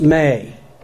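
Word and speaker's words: me (μή): But in Greek, the basic term for no is pronounced me, M-E, if you want to anglicize it, me.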